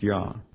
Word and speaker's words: Yah: Looking into an interlinear or into the Hebrew, we find that that is a yod and a hey. And it is correctly pronounced Yah.